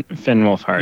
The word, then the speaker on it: Wolfhard: Finn Wolfhard. – Finn Wolfhard.